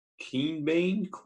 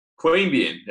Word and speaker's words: Queanbeyan: Queanbeyan Leagues Club? – Queanbeyan, there you go.